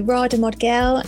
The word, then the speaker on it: Radha Modgil: Yes, so Radha Modgil and she, her.